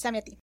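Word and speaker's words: Zamiatin: de un escritor Samyant, una cosa así, no logro la pronunciación del apellido. Gracias, Samyatin.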